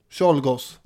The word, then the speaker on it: Czolgosz: Ska jag ge mig på att uttala det? – Ja, försök. – Lon Czolgosz. – Czolgosz. – Ja. – Det kan man ju säga.